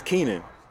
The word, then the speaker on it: Kenion: Um, my real name is Keenan, but it's spelled K E N I O N.